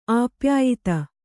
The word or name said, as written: ಆಪ್ಯಾಯಿತ